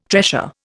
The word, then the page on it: tressure: Indian